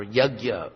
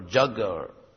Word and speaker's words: yajna: And the topic is sacrifice or yajna or jagga or yajna or there are many pronunciations because I guess even in ancient times, J-N was not the easiest thing to pronounce. So in India they kind of pronounce it non-phonetically, yajna literally.